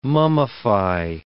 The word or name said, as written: mummify